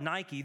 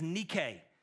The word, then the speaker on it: Nike: In fact, we call it Nike. The correct pronunciation is Nike, and Nike is Greek for victory.